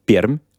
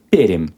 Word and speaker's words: Пермь: Неправильно говорить Пермь, правильно говорить? Пермь. – Перим, Мягкая R, мягкая. Чуть-чуть Е можно добавить.